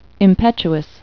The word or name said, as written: impetuous